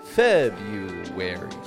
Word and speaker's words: February: For example, do you ever wonder why the word February isn't actually pronounced with the R? February. Notice I totally dropped that R out of there.